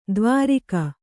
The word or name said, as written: ದ್ವಾರಿಗ